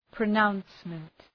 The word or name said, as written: pronouncement